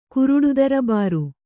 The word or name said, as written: ಕುರುಡುದರ್ಬಾರು